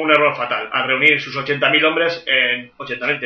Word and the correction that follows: Alesia: tenía en, Alesia, ¿no?